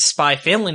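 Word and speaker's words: SPY×FAMILY: So I got a copy of the first issue of Spy Family, and she was pronouncing it Spy Family, and I was like, wow, I'm really impressed. You know the X is silent.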